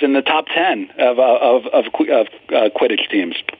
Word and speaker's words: Quidditch: 2100, my bet's on Kittich. Is that how you pronounce it? Quidditch? – Quidditch.